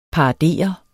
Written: paradere

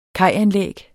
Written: kajanlæg

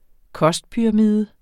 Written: kostpyramide

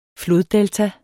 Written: floddelta

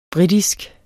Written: britisk

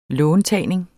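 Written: låntagning

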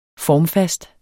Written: formfast

